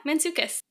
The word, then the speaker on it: Mantzoukas: And I also learned from this that she does it with, and I'm going to pronounce his name wrong, I'm sure, but Jason Mantzoukas, right? – That's right. Yeah, Mantzoukas. From how I've heard it.